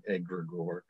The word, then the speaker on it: egregore: Well, and for the purists out there, we are mispronouncing the word. It is one of those potato-potato situations, but since it is Greek, everyone gets an excuse at pronouncing it poorly. And so we're just going to go with the Anglified, the Americanized pronunciation, egregore, okay?